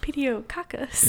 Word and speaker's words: Pediococcus: I can't pronounce Pediococcus. – Pediococcus? – Pediococcus.